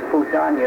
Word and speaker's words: Fudan: And I also there was also the exchange with Fudan University.